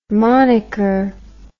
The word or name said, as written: monniker